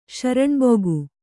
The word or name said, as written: ಶರಣ್ಬುಗು